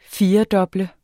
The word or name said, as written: firedoble